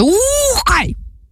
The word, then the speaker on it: Ухкай: Ми говоримо про монгольський гурт, який називається «Ухкай». Отак він називається, тому що з наголосом, от, власне, на перший склад треба, і довгим звуком «У», і коли вимовляєш це, старайся робити трохи так з живота.